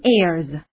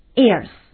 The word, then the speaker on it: ears: 那么我们大家都知道啊，耳朵 ear、 ear，那么两只耳朵我们可以说 two ears、 two ears。那么在这里发音我要强调一下啊，耳朵的复数的发音不要说成 ears 啊，这样是不对的，那后面的要浊化 ears。